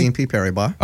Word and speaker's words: BNP Paribas: BNP Paribas.